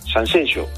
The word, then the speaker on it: Sanxenxo: Cuando vamos a dar alguna ponencia, sobre todo fuera, es decir, en el resto de España, en las ponencias al final siempre ponemos el tema Sanxenxo como Shakira.